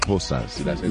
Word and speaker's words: Xhosa: You know, the Zulus down here, the Xhosa. – Yeah, you said it.